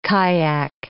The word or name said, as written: kyack